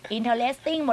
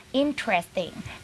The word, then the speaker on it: interesting: interesting หมดเลยค่ะไม่ได้นะคะต้องออกเสียงว่า interesting นะคะ